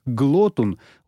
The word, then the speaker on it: глотун: И, должно быть, объяснял неоднократно и темпераментно, потому как даже наши батарейные офицеры, иначе как «глотун» — ударение на первом слоге, его не называли.